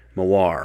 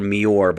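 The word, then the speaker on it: moiré: Well, I always go for Mior, but I know that's wrong. So Moir, I believe, is the correct pronunciation.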